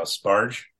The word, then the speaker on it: Sbarge: It's directed by Raphael Sbarge.